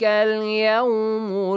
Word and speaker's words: اليوم: ذلك اليوم الحق. – ذلك يوم الحق. – Oh, Alif and Lam, they are written. You have to pronounce them as well. – Yes. – Okay. ذلك اليوم الحق.